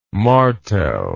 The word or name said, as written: martel